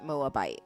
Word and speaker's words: Moabite: Naomi and her Moab, Moabite, Moabite daughters in I think you're saying it right, Moabite.